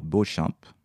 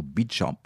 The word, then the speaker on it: Beauchamp: nous a menés dans la tour Beauchamp, ou Beauchamp comme on prononce là-bas, du nom du premier prisonnier qui fut enfermé, ceci à la fin du XIVe siècle.